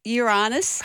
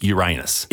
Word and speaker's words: Uranus: You got fair up my Uranus.